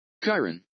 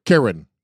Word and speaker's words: Chyron: How do you pronounce Chyron? Here we go. – What's the name? What are they called? – Ready? He's going to play it. – Chyron. – There you go. Chyron.